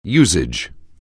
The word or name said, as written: usage